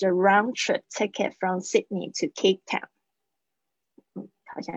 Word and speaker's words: Sydney: Sydney 那个 D 很轻的 Sydney